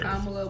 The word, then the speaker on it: Kamala: Kamala Harris. – Kamala, we hope it's Kamala.